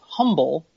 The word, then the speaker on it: Humble: Humble, H-U-M-B-L-E, but in fact, it is more fucking Texas than that. The name of the ISD is actually Humble, with no pronunciation of the H.